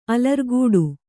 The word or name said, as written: ಅಲರ್ಗಟ್ಟು